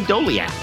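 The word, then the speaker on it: Doleac: How do you say this guy's name? Dalek? Uh, Adam Doliac.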